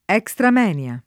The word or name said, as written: extra moenia